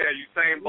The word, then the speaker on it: Usain: Hussein Bolt. How do you pronounce Bolt first name? – Yeah, Usain Bolt.